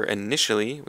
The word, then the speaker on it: initially: So for initially, we have the emphasis on the second syllable, initially, which is an adverb that means 最初 or 开头.